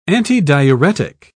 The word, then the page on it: antidiuretic: British English